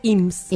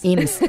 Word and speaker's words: Eames: Que es este tipo de sella IMS o EAMS. ¿EAMES? – Estuve investigando, se dice IMSS. – IMSS, ok, se escribe EAMES, por si ustedes los quieren buscar.